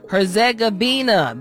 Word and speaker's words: Herzegovina: Herzegovina. I probably said that wrong. Let me try one more time. Herzegovina, there it is, at 8 p.m.